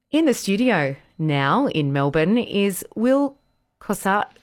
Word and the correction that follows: Kostakis: Oh, I knew I'd say this wrong. Kostakis.